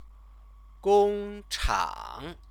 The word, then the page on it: gongchang: chinese